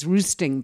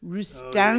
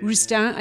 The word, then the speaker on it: Roustan: I know it looks like it's Roosting, but it's Roustan. – Oh, yeah. – Roustan.